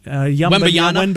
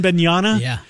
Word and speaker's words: Wembanyama: How do you say the kid's name? – Wembanyama. – Wembanyama.